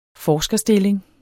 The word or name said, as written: forskerstilling